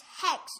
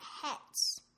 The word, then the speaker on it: Hux: And I liked the other part when Poe said to Hex, and then Hex said, "It's Hex, not Hetz."